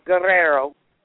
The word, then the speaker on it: Guerrero: I should say it properly, Guerrero.